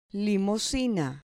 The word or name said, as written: limosina